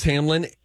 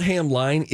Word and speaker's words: Hamline: The fact that Hamlin University is pronounced Hamlin, and there's an E at the end, which makes it a long I sound, and it's not Hamline, is nuts.